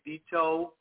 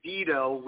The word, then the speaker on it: Vito: That's how you say it, Vito Marola. Sometimes people say Vito with a D, but that's okay also.